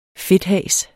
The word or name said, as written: fedthas